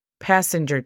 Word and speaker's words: passenger: La pronunciación fonética es P-A-S-E-N-G-R nota que no hay vocales entre la G y la R y hay una razón por la cual no ponemos vocal ahí. Passenger. Nos pasamos de inmediato de la G a la R. Passenger. Train es T-R-E-I-N.